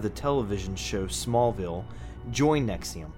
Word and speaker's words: NXIVM: The name of this dangerous cult is Nexium, spelled N-X-I-V-M, but pronounced like the medication Nexium.